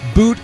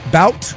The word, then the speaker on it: Bout: Uh, Brittney Griner was freed in a prisoner swap today for the Russian's arm dealer Victor uh, Boot or Bout. Uh, I've heard it pronounced both ways.